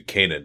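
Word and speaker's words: Canaan: And then we'll just cut 2 guys so we can keep Isaiah Cannon. And then we're going to it's actually Kanan now. It's back to the way it's actually pronounced in the Bible.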